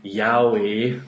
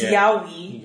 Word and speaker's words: yaoi: I started reading yaoi when I was... – Wait, you're pronouncing it wrong. It's yaoi.